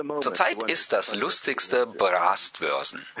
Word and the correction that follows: Brustwarzen: Das wird mit U geschrieben und das sprechen wir hier nicht wie ein A aus. Also gerade andersrum, das A und das U.